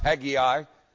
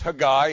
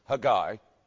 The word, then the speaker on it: Haggai: And as I said this morning, Haggai, or as we like to say it here in the South, Haggai, but it's Haggai in Hebrew. Remember, anytime you see doubled consonants, both of them are pronounced, and generally the second syllable takes the emphasis. So it's Haggai.